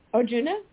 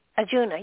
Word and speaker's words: Arjuna: Arjuna? – He was Arjuna, yes.